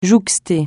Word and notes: jouxter: francais

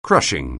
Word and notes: crushing: anglais

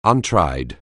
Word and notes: untried: anglais

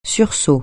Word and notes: sursaut: French

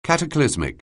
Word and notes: cataclysmic: anglais

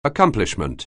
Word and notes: accomplishment: anglais

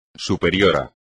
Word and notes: superior: espagnol

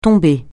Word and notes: tombée: francais

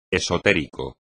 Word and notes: esotérico: espagnol

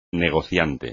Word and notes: negociante: espagnol

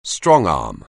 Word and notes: strongarm: anglais

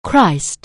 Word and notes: Christ: anglais

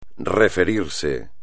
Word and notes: referirse: espagnol

